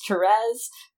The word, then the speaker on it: Therese: oh, Therese. I'm like, nope, it's Therese.